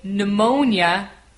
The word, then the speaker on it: pneumonia: Right, this word begins with a P and then an N. Again, we don't pronounce the P. We start the word with an N. We say pneumonia, even though we have a P at the beginning.